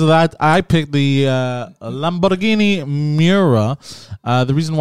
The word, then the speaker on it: Lamborghini: Because Mr. Lamborghini bought a Ferrari. Dude, just pronounce it correctly, Lamborghini. – I did Lamborghini. – You're saying Guinea. It's Guinea. Lamborghini.